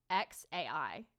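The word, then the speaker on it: X Æ A-12: Okay, apparently it's pronounced, I did look it up, it's pronounced X as in the letter, and then AI as in like artificial intelligence, and then I don't know what the A-12 is supposed to be pronounced like, but it's XAI is how his name is pronounced.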